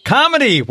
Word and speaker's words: Kamini: Kamini, welcome to the show.